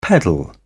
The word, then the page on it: pedal: British English